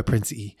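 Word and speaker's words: Prince Ea: It's spelled Prince and then EA. And I'm pretty sure he goes by Prince E.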